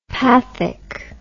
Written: pathic

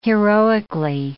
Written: heroically

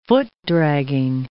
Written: foot-dragging

